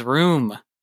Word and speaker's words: rheum: Isn't it, I don't know how to say it, room, R-H-E-U-M? – I also don't know how to say it, but it is room.